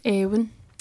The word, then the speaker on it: Éowyn: So it's not Owen or Iain. I think it's Éowyn. – Éowyn.